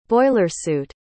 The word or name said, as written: boilersuit